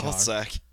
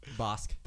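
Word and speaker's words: Bossk: Bossack. – Well, how do you pronounce it? Bossack? – Bosk. – Bosk.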